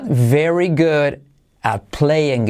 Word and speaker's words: at: Y la T de at tampoco casi se dice.